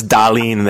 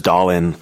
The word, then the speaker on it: Dahlin: and it really helped me nail the Rasmus Dahlin there rather than going Rasmus Dahlin.